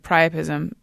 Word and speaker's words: priapism: And by the way, priapism, which I'm possibly pronouncing wrong. – No, that's right.